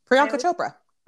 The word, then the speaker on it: Priyanka Chopra: Priyanka Chopra.